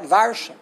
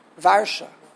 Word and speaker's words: Varsha: So W, how do you say Warsaw in Yiddish? There's no W. So. What? Varsha. Right. The V. Varsha. Warsaw.